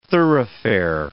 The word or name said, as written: thoroughfare